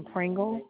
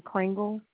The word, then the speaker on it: Pringle: It's Adrian Pringle. – Can you spell that for me? – Adrienne. Last name Pringle, like the potato chip. – I'm sorry, you said A-D-R... – I-E-N-N-E. N-N-E.